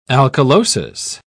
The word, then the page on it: alkalosis: British English